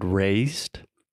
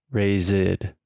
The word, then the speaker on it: raised: They say raised, raised. So they're adding that extra ED sound when most of the time it's not necessary. So it's simply raised. So just imagine a T sound at the end.